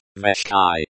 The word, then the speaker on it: вещи: Veshchai means things or belongings and is pronounced as veshchai. The sha is pronounced as shch.